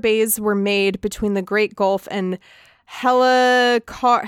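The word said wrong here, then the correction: Helcaraxë: The Helcaraxa.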